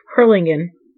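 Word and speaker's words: Harlingen: Herlinger. – Herlingen. – Okay, I was wrong again. – Yeah, Herlingen. – Okay. Yeah. – So thank you, Herlingen. Thank you, Herlingen.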